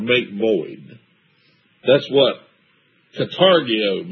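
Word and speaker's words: Katargeo (καταργέω): The word destroy is kategeo. And there's I want to read you the definition to kategeo there in verse 14. Katargeo. I'm sorry, I mispronounced it. Katargeo.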